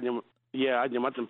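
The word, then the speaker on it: Adnyamathanha: And that's the Adnyamutna people, is it? Did I say it right? – Adnyamutna people. Yeah.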